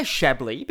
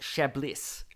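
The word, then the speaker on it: Chablis: Okay, I'm going to say Shabley because that's actually the way you pronounce this wine.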